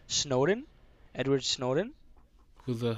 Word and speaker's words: Snowden: Edward Edward Snowden. Snowden.